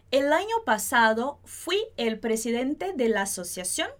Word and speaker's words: fui: Em português, a gente fala fui. Em espanhol, a gente vai colocar a parte mais forte no i, como está escrito aqui. Seria como dizer fui, fui.